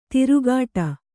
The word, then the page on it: ತಿರುಗಾಟ: tirugāṭa